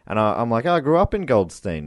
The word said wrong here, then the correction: Goldstein: He's like, actually, it's pronounced Goldstein.